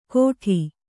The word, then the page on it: ಘೋಟಿ: kannada